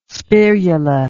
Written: spirula

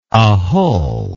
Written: ahull

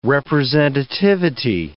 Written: representativity

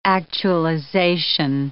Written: actualization

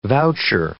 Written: voucher

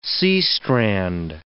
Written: seastrand

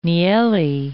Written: nielli

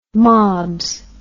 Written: mobs

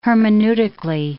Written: hermeneutically

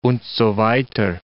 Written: und so weiter

